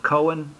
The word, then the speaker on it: Kohen: And so they covered up the embarrassment by mistranslating the Hebrew word Kohen, which is pronounced Cohen in English, C-O-H-E-N.